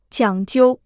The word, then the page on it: 讲究: jiǎng jiu